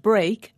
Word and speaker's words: break: And speak and break are pronounced differently, although they both have E-A-K at the end.